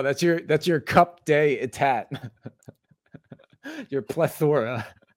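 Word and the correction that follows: coup d'état: You just butchered the pronunciation. That's what I'm saying. That's your coupe day etat.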